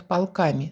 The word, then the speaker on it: полками: Кроме того, скамейки в парном отделении, их называют полками с ударением на «а», делают из ольхи.